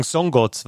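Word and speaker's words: Szongoth: Imre Sabic, genau, SZ wird wie ein scharfes S sozusagen oder ein stimmhaftes S ausgesprochen, also müsste es Domon Songot sagen.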